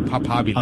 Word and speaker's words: papabile: So papabile. – I'm fairly certain that in Latin, every actual letter is pronounced. – Yeah.